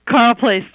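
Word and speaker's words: Carle Place: I understand your hometown is this pronounced Carly Place, New York? – Carl Place, New York.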